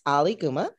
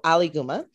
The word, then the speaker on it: Aliguma: It's Alaguma. And the way that I help people to pronounce it correctly is think of Muhammad Ali, then Guma. So Alaguma.